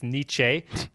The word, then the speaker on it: Nietzsche: And they get to have a moment of teenage connection where he corrects his pronunciation. It's Nietzsche, and gives him a little bit.